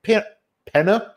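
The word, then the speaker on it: Peña: but there is a cameo from a very young Michael Penner is how you say his name.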